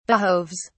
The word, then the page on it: behoves: British English